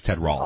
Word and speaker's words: Ted Rall: You need to get the pronunciation right. It's Ted Rall.